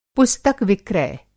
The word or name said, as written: पुस्तक-विक्रय